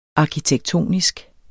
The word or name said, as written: Arkitektonisk